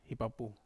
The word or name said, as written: hapapu